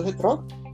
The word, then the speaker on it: retrógrada: Eu falei certo, retrógrada? É isso? É? Certo, é isso aí.